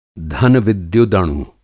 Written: धन-विद्युदणु

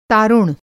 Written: तारुण

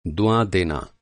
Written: दुआ

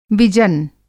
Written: विजन

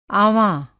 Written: आँवाँ

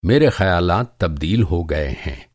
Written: तब्दील

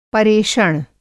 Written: परेषण

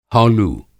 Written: हौलू

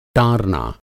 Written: टारना

